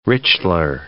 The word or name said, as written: Richler